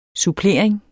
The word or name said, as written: supplering